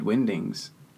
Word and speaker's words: Wingdings: How do you say it? – Wingding. – Wingding. – Yeah. Like why a wing that flies and a ding, like a ding bat.